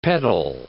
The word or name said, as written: peddle